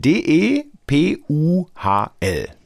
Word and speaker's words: Depuhl: Hallo Frau Depuhl. – Guten Morgen, hallo Mann. – Wir buchstabieren DE. P-U-H-L, Betonung richtig. Auf dem D-E, also Depuhl.